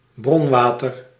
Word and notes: water: [ˈwɑ.ɾɚ]; [ˈwɔː.tə]; /ˈwɔːtə(ɹ)/

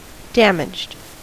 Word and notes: damaged: American English; [ˈdæm.ɪdʒd]

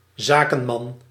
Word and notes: zakenman: /ˈzakəmɑn/; /za.kǝn.mɑn/

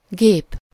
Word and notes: machinery: American English; [mə.ˈʃi.nə.ɹi]

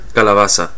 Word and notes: kaali: /ˈkɑː.li/

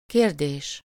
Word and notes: vraag: /vraːx/; /vɾaɣ/

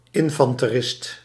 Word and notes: солдат: /sɐlˈdat/

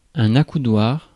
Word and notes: accoudoir: [a.ku.dwaʁ]

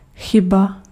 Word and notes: chyba: [ˈxɪba]

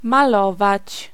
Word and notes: dépeindre: /de.pɛ̃dʁ/

